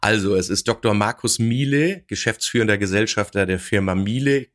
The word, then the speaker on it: miele: Miele wird es geschrieben. Ausgesprochen wird es natürlich Miele.